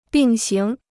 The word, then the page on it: 并行: bìng xíng